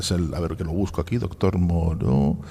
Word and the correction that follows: Murro: ¡Es Murro! – ¿Qué? – ¡Murro! ¡No, Moro! – Hay un señor que está diciendo cosas que no pronunciamos bien el apellido, me parece que está diciendo. ¿Quién grita? – ¡Soy el científico del WhatsApp! ¡El doctor Murro! – ¿Pero qué hace usted en el público? O sea, ¿usted puede acercarse, por favor? – Sí, suba al escenario. Véngase usted aquí. Doctor Murro. – Murro. – Bueno, disculpe. A ver, Murro. Disculpe mi mala pronunciación.